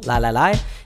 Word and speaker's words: lai: Now in America, when we sing nigunim, often we use the syllable li-li-li, right? Not L-I-E, but L-A-I, just as a nonsense syllable, li-li-li.